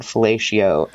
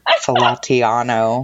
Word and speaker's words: fellatio: It was like with an Italian flair, it was like Falatiano or something like that.